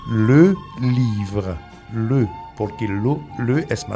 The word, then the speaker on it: les: Nosotros tenemos solamente le, L-E-S. No se pronuncia les, se pronuncia le.